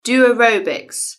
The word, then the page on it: do aerobics: British English